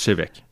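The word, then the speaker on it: Té Bheag: peut, moi, je m'amuse à dire comme il est écrit sur la bouteille, c'est te bag. Un tea bag Puis ça se prononce Chevec. – Chevec, oui. Ouais.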